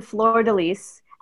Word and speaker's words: Fleur de Lys: So we're talking about this woman, Fleur de Lys, and I Googled that, and that is how it's pronounced.